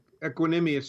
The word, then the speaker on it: Equanimeous: And that, hopefully I say his name right, Equanimeous St. Brown? – Equanimeous St. Brown. – Equanimeous, okay.